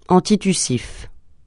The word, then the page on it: antitussif: francais